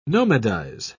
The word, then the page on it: nomadize: British English